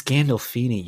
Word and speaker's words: Gandolfini: It's Gandolfini, you. – Gandalf. James Gandalf. I don't know how to pronounce his last name.